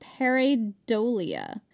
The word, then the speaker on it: pareidolia: It's a phenomenon called pareidolia. Pretty sure I said that right. – You did.